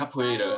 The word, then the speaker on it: capoeira: forgive me if I say this wrong, the capoeira, like fighting capoeira. – Style. Capoeira. Okay. There we go.